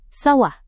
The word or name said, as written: sawah